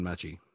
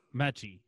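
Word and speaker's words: Metchie: Did you see that block by your boy, was it Mincy? How do you say his name? The wide out for Bama. – John Mechie. – Mechie.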